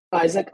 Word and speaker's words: Isaac: Isaac, I-S-A-A-C, and my last name is Sebbag, S-E-B-B-A-G.